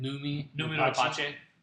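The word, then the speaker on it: Noomi Rapace: Nomi Rapace.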